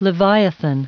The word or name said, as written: leviathan